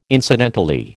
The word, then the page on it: incidentally: American English